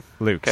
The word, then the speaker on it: Lewk: Are we doing L-E-W-K? – L-E-W-K. Luke.